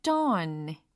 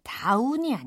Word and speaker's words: dawn: at dawn 이라고 했어요. 다운이 아니라 dawn. 이렇게 발음이 됩니다. 그래서 새벽이라는 단어로 D A W N 이 철자를 발음하면 다운이 아니에요. dawn, dawn 요게 됩니다.